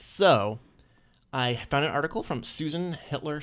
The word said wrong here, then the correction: Heitler: She's a Heitler, not Hitler. – H-I-T-T-L-E-R? – H-E-I-T-L-E-R.